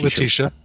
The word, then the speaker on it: Letitia: So several months ago, Letitia Wright, or I don't know how to say her first name, but I believe that's right, Letitia. Yeah.